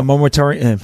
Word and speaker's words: moratorium: I think it's like called a mementorium or something like that, mementorium on like evictions. So for like residential, moratorium. Now I can't say it right.